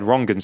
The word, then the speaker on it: Rangan: Because, you know, the way my name is spelt, it is Rangan, you know, mm-hmm. Phonetically in English. But mm-hmm. You've come and said Rongan straight away.